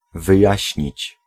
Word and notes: verklaren: /vɛrˈklarə(n)/

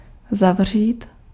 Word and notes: boucher: [bu.ʃe]; France

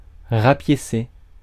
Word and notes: patch: /pætʃ/

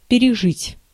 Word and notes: ondergaan: /ˌɔndərˈɣaːn/; /ˈɔndərˌɣaːn/

